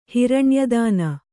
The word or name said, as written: ಹಿರಣ್ಯದಾನಿ